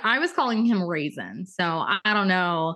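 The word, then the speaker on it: Rhysand: First of all, unless you had that pronunciation page, you had no idea how to even say his name. – But my girlfriend said that she knows that his name is Reason because she had the pronunciation. But in her mind, no self-respecting man can be Reese and be like, so she calls him Ryson anyway.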